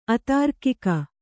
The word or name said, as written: ಅತಾರ್ಕಿಕ